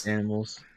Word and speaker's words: Danimals: How do you say it? – Danimals. – It's like Danimals, a man named Dan became an animal.